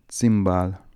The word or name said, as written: zimbal